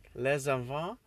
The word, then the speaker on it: les enfants: But les enfants, I actually did pronounce the S now, but only because there was another word behind it.